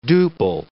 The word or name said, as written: duple